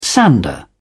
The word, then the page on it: sander: British English